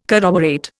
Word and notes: corroborate: Indian

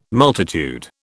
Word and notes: multitude: British English

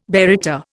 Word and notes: baryta: Indian

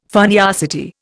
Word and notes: funniosity: Indian

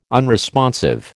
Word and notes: unresponsive: American English

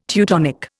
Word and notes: Teutonic: Indian

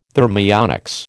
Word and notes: thermionics: American English

